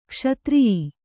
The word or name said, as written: ಕ್ಷತ್ರಿಯಿ